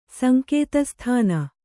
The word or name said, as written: ಸಂಕೇತಸ್ಥಾನ